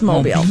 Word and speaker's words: mobile: But you're pronouncing the E too hard. It's just mobile.